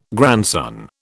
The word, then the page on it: grandson: British English